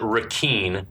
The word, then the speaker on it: Requin: We finally find out from the captain that Ponytail's name is, he pronounces it Requin, which is spelled R-E-Q-U-I-N, which is the French word requin, which is the French word for shark.